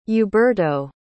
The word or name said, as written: uberto